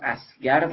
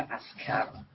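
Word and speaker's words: عسکر: حالا این خونسا شدگی ممکنه بعد از واژه هم اتفاق بیفته، مثل واژه عربی اسکر که ممکنه در تلفظ اسگر تلفظ بشه. حالا چون در عربی نه حرف گ داریم و نه صدای گ، تلفظ و نوشتن اونها با گ نادرسته، برای این حتما باید تلفظ بشه اسکر یا نوشته بشه اسکر.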